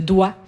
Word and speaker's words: doigt: Si on prend l'exemple de « doigt », on a ajouté G à doigt, mais c'est pas parce qu'on l'a déjà prononcé, c'est parce que ça vient de digitum en latin et on voulait montrer la noblesse, la lignée noble du français.